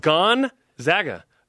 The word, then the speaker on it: Gonzaga: It's not a gun. – Gone. – It's Gonzaga. Gonzaga.